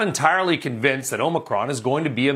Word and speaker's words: Omicron: And by the way, that is how it's pronounced. It is Omicron. It's not Omicron or anything else.